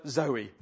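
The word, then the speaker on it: Zoe (ζωή): It sounds like the word Zoe, the name Zoe. I thought you might. It sounds like the name Zoe.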